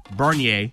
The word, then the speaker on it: Bernier: Backup goalie, Jonathan Bernier. Am I saying that right? I nailed it, Jack. – I'm so proud of you. We didn't even put a name pronunciation on there, and you still nailed it.